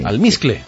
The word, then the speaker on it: almizcle: Y que ese alpiste o almiste, ¿cómo se dice? – Almizcle. – Almizcle.